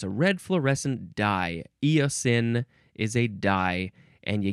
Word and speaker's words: eosin: Here we go with eosin. E-O-S-I-N. Eosin. You can also spell it with an E at the end, and it can be pronounced eosin also or eosine.